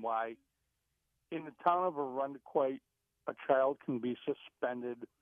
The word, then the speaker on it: Irondequoit: Also, notice that local pronunciation, Irondequoit. Irondequoit.